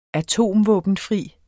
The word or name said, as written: atomvåbenfri